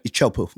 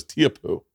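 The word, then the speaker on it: Teahupo'o: So is that how you say it? Chopu. Yeah, I thought it was Tiapu.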